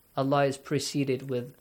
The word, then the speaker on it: الله: the word Allah is pronounced with an a sound بالله وَأَخْلَصُوا دِينَهُمْ أَخْلَصُوا خَا has a heavy sound.